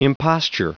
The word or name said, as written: imposture